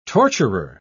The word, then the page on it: torturer: British English